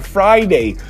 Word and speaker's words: Friday: Já a sexta-feira tão amada por tantos, é só lembrar do verbo to fry, que é fritar. Não é a mesma escrita, mas é a mesma pronúncia, Friday.